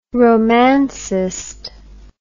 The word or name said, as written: romancist